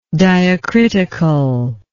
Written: diacritically

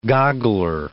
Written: goggler

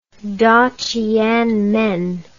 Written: daqianmen

